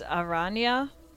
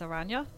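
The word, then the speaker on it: Araneae: I think it's Aranya, because in Spanish, spider is Aranya. Like Aranya, you know? So I think it's probably playing off of that, but that's just my guess. Aranya.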